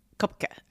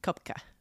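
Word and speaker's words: Koepcke: She was raised, she's the daughter of German zoologists Maria and Hans Wilhelm Kopecky. Kopke. I'm like not good with pronunciation of foreign names. – It's only because I heard it. – Kopke.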